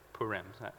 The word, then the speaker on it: Purim: I think that's how you say it, Purim. Is that right, Mary? Yeah, okay. Just say yes.